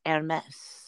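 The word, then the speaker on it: Hermès: The H is silent because it's French, right? Hermes.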